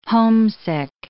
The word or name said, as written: homesick